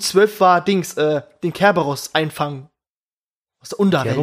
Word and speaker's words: Kerberos: Ah, okay, ich kenne als Cerberus mit, mit C an der Aussprache. Ja, Kerberos wie Chemie oder China.